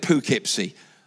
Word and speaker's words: Poughkeepsie: We were driving down, you always tell me off for this, I always call it Poughkeepsie.